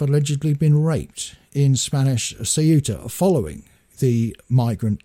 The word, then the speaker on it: Ceuta: I was pronouncing it Quetta. And that's because I'd watched news reports on it by the BBC and by GB News. And they were all referring to it as Quetta. But apparently it is Ceuta, the way that it is actually pronounced.